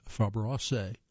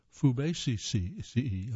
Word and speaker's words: Fabaceae: family called Fabaceae, generally pronounced Fabaceae. Oh, I guess I pronounced that wrong then.